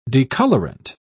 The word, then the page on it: decolorant: British English